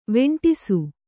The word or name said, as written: ವೆಂಟಿಸು